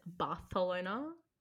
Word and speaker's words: Barcelona: So you actually need to pronounce it Barcelona. – With a lisp. – Yeah, because the king of Spain used to have a lisp, and that's how he used to say it.